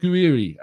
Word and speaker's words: Gouiri: Manu telling me you pronounce it Gouiri.